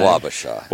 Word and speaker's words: Wabasha: Wabasha.